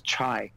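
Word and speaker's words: Chai (拆): That's the character which is pronounced Chai, which means to demolish.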